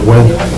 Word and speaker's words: Nguyen: Oh, no, Dr. Nguyen. – Nguyen. – Why does it say? – Because that's the way you. – Oh, that's just the way you pronounce it. – But you pronounce it. – Are you kidding me? – Yeah. – I apologize, wow. N-G-U-Y-E-N is Nguyen.